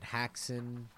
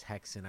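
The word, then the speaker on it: Häxan: And I know I pronounced it Haxen when we said what we were going to do this week, but the correct pronunciation is Hexen.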